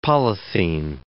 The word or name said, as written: polythene